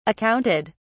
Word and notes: accounted: British English

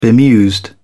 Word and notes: bemused: British English

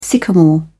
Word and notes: sycamore: British English

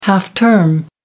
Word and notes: half-term: American English

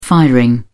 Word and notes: firing: British English

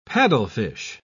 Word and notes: paddlefish: British English

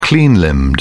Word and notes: clean-limbed: British English

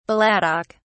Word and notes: balladic: British English

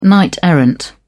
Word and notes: knight-errant: British English

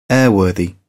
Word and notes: airworthy: British English

